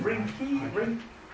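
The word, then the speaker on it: Reinke: I would say my favourite kind of writer on this stuff is Tony Rink. How do you say his name? Rinke.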